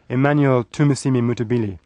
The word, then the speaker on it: Emmanuel Tumusiimi Mutebile: He's called Emmanuel Tumusiimi Mutebile.